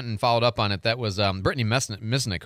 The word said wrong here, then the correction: Misencic: Misenchik, I think is how you say her last name. M-I-S-E-N-C-I-C, new with the PNJ.